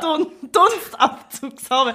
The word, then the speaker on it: Dunstabzugshaube: und ich kann Dumpfabzugshaube nicht ausspreche. Ah. Dumm, dumpf. Nicht dumm, ja. Dunstabzugshaube.